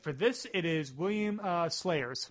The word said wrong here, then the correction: Salyers: Or S-A-L-Y-E-R-S. Salyers? Pronunciation is not my strongest suit. I realize that. And you feel like that's kind of ironic in an audio-based format. – I would call that Salyers.